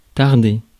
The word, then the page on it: tarder: [taʁ.de]